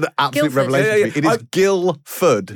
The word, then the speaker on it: Guildford: I love how you call it Guildford. You know the D is silent. – It's Guildford.